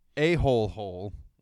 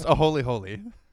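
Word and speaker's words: āholehole: It's definitely not pronounced that way. It's Hawaiian. – All right. It's pronounced a holy holy.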